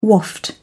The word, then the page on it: waft: British English